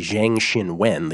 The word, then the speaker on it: Zhang Xinwen: It's actually Zhang Xinwen, the proper way to say that.